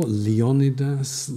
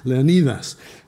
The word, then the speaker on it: Leonidas: or Leonidas, as the Americans say. My one credit in a movie was for the movie 300. I take no credit for the credit, but I was asked, how do you pronounce Leonidas? And I said, go with the modern Greek. It's neither British English nor American English, it's Greek, Leonidas.